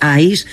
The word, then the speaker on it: ICE: escrito ICE, se pronuncia ICE,